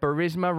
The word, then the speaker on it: Burisma: Burisma, rhymes with charisma, Ukrainian gas company.